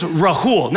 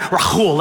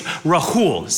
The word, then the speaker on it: Rahul: See, my name is Rahul, not Rahul like Habakkuk. Okay, it's Rahul, simple H.